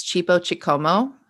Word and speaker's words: Chipo Chikomo: It's Chipo Chikomo.